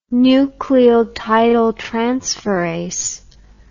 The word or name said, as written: nucleotidyltransferase